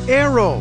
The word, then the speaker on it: Naoero: This program is previously called the country Nauru, N-A-U-R-U. The 11,000 residents have altered Nauru to be now Aero, N-A-O-E-R-O. They're going back to the pronunciation in their native language and losing a pronunciation used by outsiders.